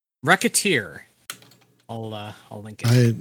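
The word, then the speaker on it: Recettear: However, the title is pronounced Racketeer, as in racketeer, considering you are running a shop and doing what you can to make as much money as you can.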